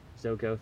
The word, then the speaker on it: Zuko: Zuko, thank you.